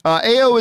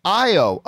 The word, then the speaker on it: Ayo: All right, Mary writes, AO is pronounced IO. Oh, see, I was talking about this. So, of course, the Dora Milaje second-in-command soldier, whose name is spelled A-Y-O, who, of course, appears at the end of Falcon and Winter Soldier in the last episode, I've always said I've never known how to pronounce it. I just pronounce it AO. But Mary is saying it's pronounced IO with no oo sound, short O, rather than I-I-O.